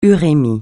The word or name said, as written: urémie